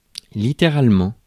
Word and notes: littéralement: France; /li.te.ʁal.mɑ̃/

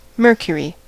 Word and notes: Mercury: American English; /ˈmɝ.kjə.ɹi/

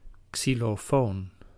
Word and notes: xylophone: /ɡzi.lɔ.fɔn/; /ksi.lɔ.fɔn/

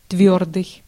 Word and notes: твёрдый: /ˈtvʲɵr.dɨj/

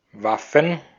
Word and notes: Waffen: /ˈvafən/; /ˈvafɱ̩/

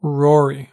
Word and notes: rory: British English; /ˈɹɔː.ɹi/; American English; /ˈɹɔɹ.i/

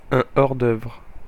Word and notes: hors d'œuvre: FR; /ɔr.dœvr/

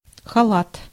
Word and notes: халат: /xɐˈlat/

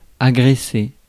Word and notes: agresser: /a.ɡʁɛ.se/; France; /a.ɡʁe.se/

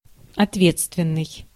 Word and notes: liable: /ˈlaɪ̯əbəl/; [ˈlaɪ̯əbɫ̩]; [ˈlaɪ̯əbəɫ]; /ˈlaɪəbl̩/

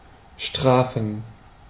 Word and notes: punish: [ˈpʌ.nɪʃ]

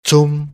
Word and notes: zum: /tsʊm/

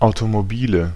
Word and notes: Automobile: /aʊ̯tomoˈbiːlə/

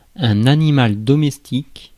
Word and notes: animal domestique: /a.ni.mal dɔ.mɛs.tik/

